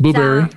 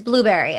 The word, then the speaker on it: Blubrry: It's Blueberry, right? – It's called Blueberry? – Yep. – Spelled with a U, but it's Blueberry. Okay. – B-L-U-B-R-R-Y, right? – Yeah. – So it's a misspelling.